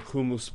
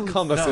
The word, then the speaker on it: Humus: You see below this very nice balcony where you're situated, dancers with headdresses shaped like the great horses of the Humus Plains. That's how it's pronounced, by the way. Priests. – How is that? Just for not... Humus. Humus. – Humus. – It's not hummus. It's humus.